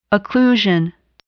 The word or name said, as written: occlusion